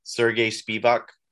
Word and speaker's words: Sergey Spivak: Sergey Spivak.